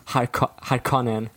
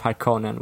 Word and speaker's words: Harkonnen: My one genuine criticism of it is that they say Harkonnen instead of Harkonnen, which is how I would pronounce it, like with a longer O.